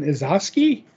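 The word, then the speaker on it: Izaakse: Which was Green Arrow number 3, written by Joshua Williamson, art by Sean Izoski. I'm guessing that's how it's said. – Yes.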